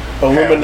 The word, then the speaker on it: aluminium: The UK pronunciation of aluminum. – Aluminium.